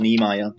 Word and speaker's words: Niemeyer: I was going to say that's almost right. Probably my fellow German friends would call me Paul Niemeyer, but you delivered it with a whole lot of character.